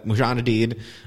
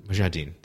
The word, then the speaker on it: Mujahideen: Rambo is trying to convince the Mujahideen, which I just mispronounced, but whatever. – Mujahideen? – Mujahideen, yeah.